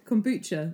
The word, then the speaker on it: kombucha: The second thing that you said. Kombucha. – Kombucha is how you pronounce it.